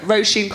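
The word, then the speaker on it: Róisín: Irish, it's an Irish name, so it's spelled like raisin. But Roisin Kanati, yeah.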